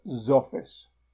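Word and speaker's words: Xfce: The three environments are Cinnamon, Mate and Zoffice, which is spelled X for X-ray, F for Foxtrot, C for Charlie and E for Echo, but which I pronounce as Zoffice.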